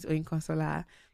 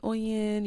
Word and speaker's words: Oyinkansola: I know I have a mixed audience of individuals from different backgrounds, and I want to make sure that, you know, we all know how to, like, you know, pronounce my name, or if you wanted to reference my podcast to someone, my name is Oyinkansala. You can call me Oyin, you can call me Oyinko, anyone works.